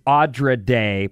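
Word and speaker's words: Andra Day: By the way, the artist's name is Audra Day, pronounced like Odd. – Oh, yeah. Audra Day. I know Audra Day. – It's not Andrea, and she is amazing.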